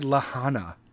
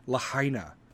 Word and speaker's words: Lahaina: You know what the other news corporations like MSNBC, CBS, ABC, they're talking about how somebody on Fox News pronounced Lahaina wrong. He said Lahana. Instead of Lahaina, Hawaii, in Maui.